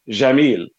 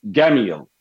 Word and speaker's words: Jamil: So in classical Arabic, it is pronounced Jamil. In Egyptian dialect Arabic, it's with a hard G and not a J, so it's Gamil.